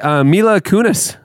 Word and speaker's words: Mila Kunis: In case you missed it this week, Mila Kunis.